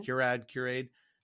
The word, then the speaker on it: Curad: It's from Cure-Aid. Is that how you say it? C-U-R-A-D? – Mm-hmm. – Cure-Aid.